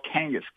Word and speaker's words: Kangas: And you just go to Finland, where my relatives are from, and you'll notice my last name is spelled Kangas, K-A-N-G-A-S.